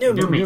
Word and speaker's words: Noomi: Is it Noomi or Noomi or Noomi? – Noomi. – Noomi. – Yeah. – Rhymes with gloomy.